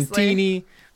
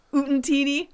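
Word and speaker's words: Utinni: Oot-teeny. – I just like how you add extra syllables in there. – What? – Oot-teeny.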